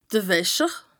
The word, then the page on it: de Wäscher: Bas-Rhin; Strasbourg